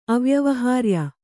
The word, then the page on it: ಅವ್ಯವಹಾರ್ಯ: avyavahārya